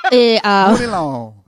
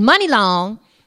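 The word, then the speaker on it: Muni Long: But it's Money Long, which I guess does make more sense. – Because it's spelled M-U-N-I.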